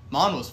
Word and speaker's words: manhwa: Manhwa's fun. – Really? I've never even heard that. – It's spelled MANWHA. It's manhwa. It's Korean.